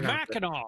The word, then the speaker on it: Mackinac: Did you call it Mackinac? – Mackinac. – Is that how you pronounce it? – That's how you pronounce Mackinac.